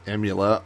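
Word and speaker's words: Aemula: It's A-E-M-U-L-A, but I believe it's Emula Oldies,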